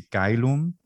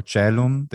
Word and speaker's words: caelum: like we see caelum or caelum, the AE in the other names, I mean sky, caelum or caelum, that's the standard spelling with the two pronunciations.